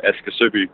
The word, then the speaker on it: Aske Soby: Yeah, I would pronounce it Aske, Soby.